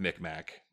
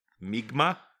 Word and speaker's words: Mi'kmaq: I just wanted to cycle around to that because your pronunciation of Mi'kmaq, which we also saw on a person who knows a lot more than us say, and it was almost like Mi'kma.